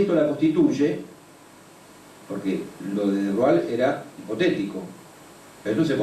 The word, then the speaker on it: de Broglie: Pero nadie había pensado en extender esto a las partículas materiales, hasta que en 1924 el físico francés Louis de Broglie, se escribe de Broglie, pero se dice de Broglie,